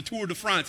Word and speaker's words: Tour de France: And the Tour de France, that's how you say it when you're a real fan.